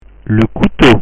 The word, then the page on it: le couteau: French